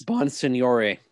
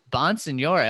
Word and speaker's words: Bonsignore: How do you say that? – Bonsignore. – Bonsignore. Oh, very French.